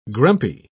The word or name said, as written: grumpy